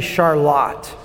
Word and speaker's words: Charlotte: There's a city in Michigan that is spelled Charlotte, but it's actually Charlotte.